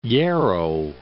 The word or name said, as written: Hierro